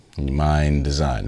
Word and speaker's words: MNDSGN: So he, Mind Design is spelled MNDSGN, Mind Design.